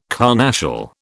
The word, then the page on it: carnassial: British English